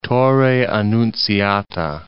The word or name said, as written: Torre Annunziata